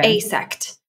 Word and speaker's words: AASECT: And I did say it right, A-A-S-E-C-T. It's an acronym.